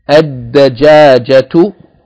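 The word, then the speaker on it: ad-dajajatu (الدجاجة): The next word, ad-dajajatu, ad-dajajatu, with shadda on the dal because it's a sun letter, and the elongation of the second syllable, dajajatu, with ta marbuta on the end.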